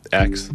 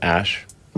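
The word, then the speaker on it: X Æ A-12: I mean, it's just X, the letter X. The AE is like... pronounced Ash.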